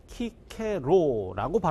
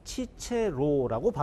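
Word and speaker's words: Cicero: 이분의 이름은 우리나라에서는 키케로라고 발음합니다. 그런데 라틴어 발음에는 이 한 사람 동일 인물에 대해서 어떤 책에서는 키케로, 또 어떤 책에서는 치체로라고 발음합니다.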